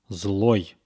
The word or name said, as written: zloy